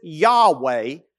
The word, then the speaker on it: Yahweh: We pronounce it Yahweh, but the way that we understood it was God saying, I am that I am. We pronounce it Yahweh in the Hebrew.